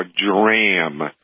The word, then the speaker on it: Dramm: And if you want a quality watering product, look for Dram. and it's spelled DRAMM on the device itself.